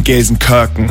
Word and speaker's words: Gelsenkirchen: Entschuldigung, Gelsenkirchen.